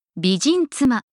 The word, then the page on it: bijin tsuma: Japanese